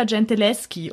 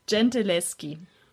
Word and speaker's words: Gentileschi: Mich hat nämlich meine Mutter gleich danach darauf aufmerksam gemacht, dass man im italienischen SCH als G ausspricht und deswegen heißt die Frau Artemisa Gentileschi.